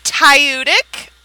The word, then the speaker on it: Tudyk: It's Tudyk. I've actually heard him say his name aloud.